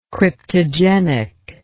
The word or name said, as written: cryptogenic